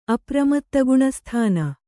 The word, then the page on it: ಅಪ್ರಮತ್ತಗುಣಸ್ಥಾನ: apramattaguṇasthāna